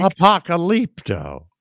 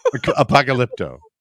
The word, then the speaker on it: Apocalypto: You have to say it right. – Apocalypto. – Thank you. – Apocalypto. – Yes.